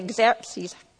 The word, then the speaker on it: Xerxes: Esther is a young Jewish girl in exile who became the wife of King Xerxes, that's how you pronounce it, ruler of Persia.